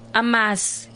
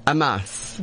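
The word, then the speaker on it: amasi: Can I teach you how to pronounce that properly? Amas. – Amas.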